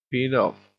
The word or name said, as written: peed off